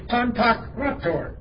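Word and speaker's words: Pantokrator: It's spelled just like it's pronounced, I think. It's P-O-N-T, Pantak, P-O-K. P-O-N, or P-A-N, excuse me. I got all in the brain. P-A-N, T-O-K, Pantak. The emphasis is Pantak, or God. R-A-T-O-R. This is a difficult word once you learn it. Pantakrator. P-A-N-T-O-K, R-A, and the emphasis upon the A, the alpha.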